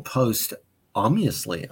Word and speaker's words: posthumously: It's posthumously? – The H is silent. – Oh, posthumously.